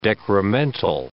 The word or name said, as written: decremental